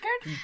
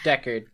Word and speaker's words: Deckard: As always, so thank you to the creators of Heart, Rowan Rook and Dick Hard. Is that how you say it? – Deckard? – Deckard.